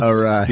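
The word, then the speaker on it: Arraez: Arias. – Yeah. I mean, Jesus Christ. But Luis, how do you say it Arise. Again? – Arise.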